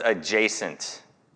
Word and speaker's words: adjacent: And, you know, they teach you all about silent, you know, letters, but they don't really teach you much about the silent D in English. Because the D there is silent, it's adjacent, but there is a D that's hiding in there, and I didn't know it at the time.